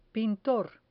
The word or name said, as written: pintor